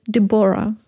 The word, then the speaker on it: Deborah: Pauline heißt nun Gina und Juliet Deborah mit der Betonung auf der zweiten Silbe.